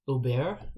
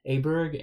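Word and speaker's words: Åberg: So Luke List ended up winning out in a 5-man playoff over Scott Stallings, Henrik Norlander, Ben Griffin, and Ludwig Uber is apparently how we're supposed to be saying his name. I think most American media has been calling him Ludwig Aberg, and apparently that is not the proper pronunciation.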